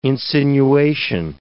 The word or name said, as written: insinuation